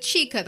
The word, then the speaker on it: CHIIKA: We said CHIIKA, that's two syllables.